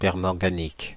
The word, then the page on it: permanganique: /pɛʁ.mɑ̃.ɡa.nik/; France (Île-de-France)